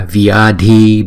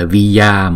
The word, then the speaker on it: vyadhi: Drave bhya, Sarva vyadhi bhya, Vya, vya, vya, không phải là vya mà là vyadhi bhya.